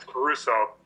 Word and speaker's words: Caruso: Because it's Caruso. – Caruso, I'm sorry. I do apologize. – Oh, that's fine. So Dan Caruso.